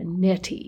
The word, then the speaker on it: neti: It's a neti? – A neti, yes. – Is that how it's pronounced? – I believe so.